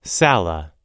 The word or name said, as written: sala